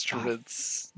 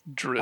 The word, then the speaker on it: Drizzt: That's how you say it? Drizzt? – Drizzt. – Isn't that it? – Drizzt. – I thought it was Drizzt. – No, it's Drizzt. Drizzt.